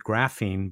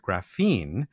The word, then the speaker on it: graphene: I've always called it graphene, but I note that you pronounce it graphene, emphasis on the second syllable.